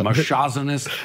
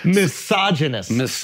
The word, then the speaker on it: misogynist: Misogynist.